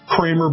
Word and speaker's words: Creamer: I think his name was Kramer, but spelled like Creamer.